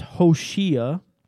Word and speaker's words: Hoshea: And Joshua was originally pronounced Hoshea, and it means salvation.